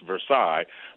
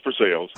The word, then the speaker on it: Versailles: It's spelled just like Paris, Versailles, but I promise you it's pronounced Versailles.